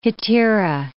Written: hetaera

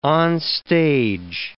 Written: onstage

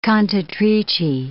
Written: cantatrici

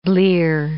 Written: blear

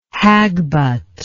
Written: hagbut

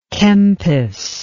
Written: Kempis

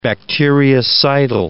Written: bacteriocidal

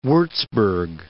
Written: Wurzburg

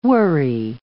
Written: worry

